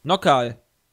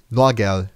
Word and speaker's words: Noagerl: Nockal. Nee, nicht Nockal. Ist, oh, mit einem, ist mit einem G. Ist halt Norgel. N-O-A-G-E-R-L. Norgel. Norgal.